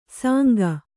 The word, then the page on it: ಸಾಂಗ: sānga